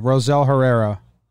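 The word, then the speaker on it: Rosell Herrera: It's early, and we've also been brainwashed by Brian Cashman, close friend of the program, that, I mean, Rozelle, are we saying his name right? I think we need to start there. Is it Rozelle? – Yeah, Rozelle Herrera. Rozelle. I mean, I'm not saying that confidently, but that's how it's phonetically, that's how it's pronounced phonetically with an American English accent.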